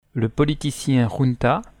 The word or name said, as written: junta